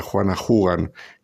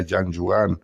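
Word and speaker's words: Jeanne Jugan: Sí, Santa Juana Jugan, que es el modo español de pronunciar su nombre francés, que sería Jean Jugan, pero aquí en España la conocemos así, como Santa Juana Jugan,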